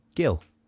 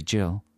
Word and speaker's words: Gill: How would you pronounce the name G-I-L-L? – Gill. – From Essex. Could be Jill. – It's Gill.